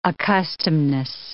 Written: accustomedness

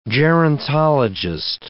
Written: gerontologist

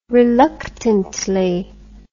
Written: reluctantly

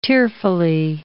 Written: tearfully

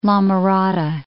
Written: La Mirada

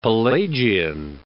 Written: Pelagian

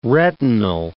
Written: retinal

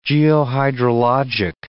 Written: geohydrologic

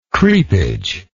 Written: creepage

